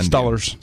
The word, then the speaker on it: Steelers: How do you say Steelers in Pittsburgh? – Stullers.